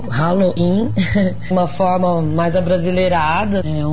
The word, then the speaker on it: Halloween: Para celebrar o Halloween, o Bartelier Gastro promove sua primeira Halloween. Não errei na pronúncia, não. É com R-A-L-O-I-N mesmo.